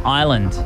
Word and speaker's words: island: Which letter is silent when pronouncing the word island? – S. – Correct.